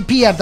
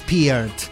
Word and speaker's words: Peart: Sí, Peart, aprendimos a decir como corresponde con el nombre, que es Peart. Que así dijo él que se pronunciaba.